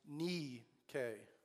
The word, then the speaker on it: Nike: It should actually be pronounced Nike, which is Greek for victory.